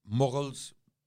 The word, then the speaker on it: Mughal: Mughals, M-U-G-H-A-L. Mm-hmm. This is the best way to pronounce Mughals.